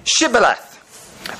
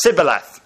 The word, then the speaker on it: Shibboleth: Anyone wanting to pass has to say Shibboleth, a word Ephraimites just cannot help pronouncing as Sibboleth.